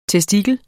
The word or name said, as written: testikel